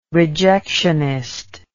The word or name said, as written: rejectionist